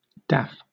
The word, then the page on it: daff: British English